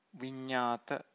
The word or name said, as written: vinnata